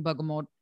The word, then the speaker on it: Bergamot: So, there should be a mission behind you starting bergamot. Okay. So bergamot is how you pronounce it. Is that correct? – Bergamot. The T is silent.